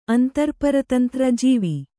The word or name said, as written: ಜಿವಿ